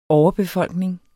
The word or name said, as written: overbefolkning